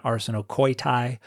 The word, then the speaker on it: arsenokoitai: And then the next word is arsenokite, or according to the Erasmian pronunciation, arsenokoitai, which would be men betters.